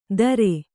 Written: ದರೆ

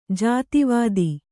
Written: ಜಾತಿವಾದಿ

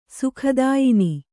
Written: ಸುಖದಾಯಿನಿ

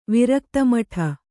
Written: ವಿರಕ್ತಮಠ

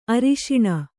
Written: ಆರುಷಣ

